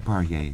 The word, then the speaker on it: Poirier: Richard Parra, is that how you pronounce that? Leslie Fielder, Ihab Hassan, and Buckminster Fuller. – Poirier, I think.